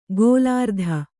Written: ಗೋಲಾರ್ಧ

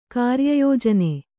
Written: ಕಾರ್ಯಯೋಜನೆ

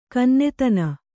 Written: ಕನ್ನೆತನ

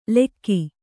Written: ಲೆಕ್ಕಿ

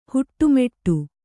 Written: ಹಿಟ್ಟುಮಟ್ಟಿ

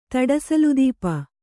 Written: ತಡಸಲುದೀಪ

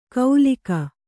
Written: ಕೌಲಿಕ

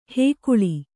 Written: ಹುಗುಳು

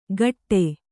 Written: ಕಟ್ಟೆ

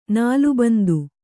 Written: ನಾಲಬಂದ

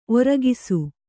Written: ಒರ್ಕೈಸು

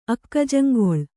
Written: ಅಕ್ಕಜಂಗೊಳ್

